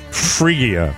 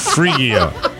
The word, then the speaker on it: Phrygia: And as you know, Midas is the name of at least three members of the Royal House of Phrygia. Did I pronounce that right, Rob? – Nailed it. – Phrygia.